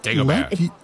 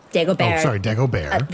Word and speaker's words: Dagobert: So, Dagobah, what Dagobert. – Dagobert. – The T is silent.